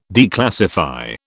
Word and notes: declassify: British English

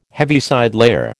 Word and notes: Heaviside layer: American English

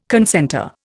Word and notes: concentre: Indian

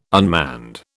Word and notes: unmanned: British English

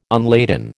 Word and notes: unladen: American English

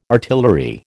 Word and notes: artillery: American English

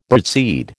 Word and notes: birdseed: American English

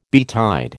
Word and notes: betide: American English

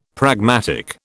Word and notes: pragmatic: British English

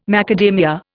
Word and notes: macadamia: Indian

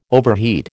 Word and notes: overheat: American English

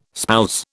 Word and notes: spouse: British English

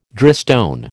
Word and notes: drystone: American English

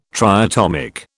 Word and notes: triatomic: British English

